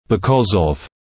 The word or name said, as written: because of